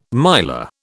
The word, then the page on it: miler: British English